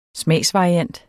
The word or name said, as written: smagsvariant